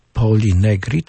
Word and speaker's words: Poli Negri: Poli Negri, tak? Dobrze wymawiam? – Tak.